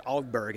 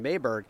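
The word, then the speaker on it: Åberg: his is Swedish, it's Ugberg, and everyone's calling him Aberg, but then many times the player himself just says, just call me Aberg, it's fine, and we'll just leave it at that.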